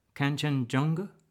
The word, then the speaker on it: Kanchenjunga: Did I say it wrong? Kanchenjunga? – No, sir. You pronounced it most correctly.